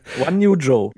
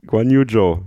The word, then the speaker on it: Guanyu Zhou: Aber ihn triggert die Aussprache von Guanyu Zhou. Also Guanyu Zhou. – Guanyu Zhou. Ne? Wir merken uns das jetzt.